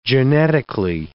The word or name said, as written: genetically